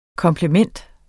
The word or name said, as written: komplement